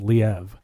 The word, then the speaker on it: Liev: You see a very young Liev Schreiber, who I don't think had really done anything up until this point. – And I think his name is pronounced Liev.